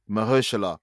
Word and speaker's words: Mahershala: How do you say my man's name? Mahershala? – Mahershala.